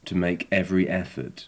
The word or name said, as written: to make every effort